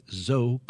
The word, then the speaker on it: Zoe: Some people say Zoe, but it's Zoe Caldwell. – Yes, you pronounce it with one syllable.